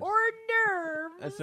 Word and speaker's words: hors d'oeuvre: Hors d'oeuvre!